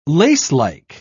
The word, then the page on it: lacelike: British English